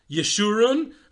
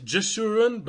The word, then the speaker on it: Yeshurun: He establishes the first ever orthodox journal, Yeshurun. I know many people mispronounce it Jeshurun, but in European languages, a J is pronounced as Y.